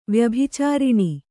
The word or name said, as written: ವ್ಯಭಿಚಾರಿಣಿ